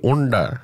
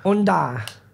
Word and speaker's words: Unda: featuring at the Araluen with his album launch called Under Wormer Are You Wonder. Singing? – Say it right. Unda. – Unda.